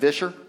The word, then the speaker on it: Vischer: A couple of weeks ago or so, Phil Vischer, kind of spelled like Fisher, but Vischer.